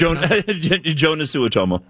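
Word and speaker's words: Joonas Suotamo: Looks like it should be a soft J. – I think it's I thought it was Jonas. – Well, there's two O's in front of it.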